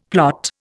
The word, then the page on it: plot: Indian